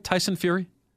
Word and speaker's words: Tyson Fury: Am I saying that right, Tyson Fury? – Yeah, you're saying it right.